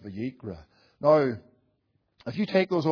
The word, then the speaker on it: Vayikra: And in the Hebrew language, those particular words are pronounced that way, Vayikra, and therefore they called it the Vayikra, with reference to how the book actually starts out.